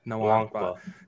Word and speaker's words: Nwankwa: And you can see with guys like Proctor and, how do you say the last name? I don't want to screw it up. – Nwankwa.